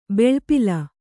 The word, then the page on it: ಬೆಳ್ಪಿಲ: kannada